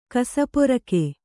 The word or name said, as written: ಕಸಪೊರಕೆ